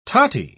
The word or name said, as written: totty